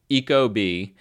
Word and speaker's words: Ecobee: And the other one would be the Ecobee, which is spelled kind of weird, but it's like eco, like eco-friendly, and then bee, like the animal, like the insect, bee. Ecobee.